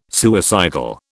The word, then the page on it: suicidal: British English